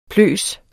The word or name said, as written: pløs